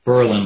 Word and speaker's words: Berlin: Berlin, like the one in Germany, pronounced with the accent between syllables, like pearl and preposition. And God forbid local residents hear you pronounce it the other way.